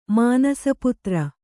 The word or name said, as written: ಮಾನಸಪುತ್ರ